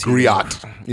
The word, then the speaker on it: griot: They actually have, and if you go to the Griot article, Griot is G-R-I-O, they actually have a video of G-R-I-O-T. – Her. – G-R-I-O-T. – No, no, G-R-I-O-T. – They don't put the T on the end? – No, okay. The T. – Cool. – I guess they figured that, you know, so-called black folks can't read, so can't pronounce stuff. We're going to just drop the T.